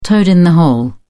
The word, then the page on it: toad-in-the-hole: British English